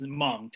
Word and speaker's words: Hmong: It's with the H, but the H is silent. So it's Hmong, just Hmong.